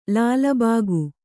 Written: ಲಾಲ್‍ಬಾಗ್

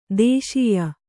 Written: ದೇಶೀಯ

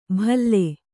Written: ಭಲ್ಲೆ

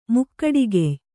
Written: ಮುಕ್ಕಡಿಗೆಯ್

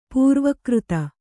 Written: ಪೂರ್ವಕೃತ

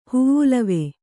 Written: ಹುವ್ವುಲವೆ

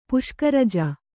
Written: ಪುಷ್ಕರಜ

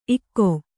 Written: ಇಕೊ

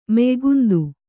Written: ಮೆಯ್ಗುಂದು

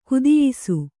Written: ಕುದಿಯಿಸು

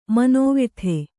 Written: ಮಾನವೀಯತೆ

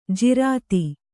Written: ಜೋರತ್ತು

